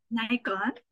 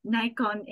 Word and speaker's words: Nikon: Nikon. Like I didn't even know how to pronounce uh, Nikon in English because all I know is Nikon.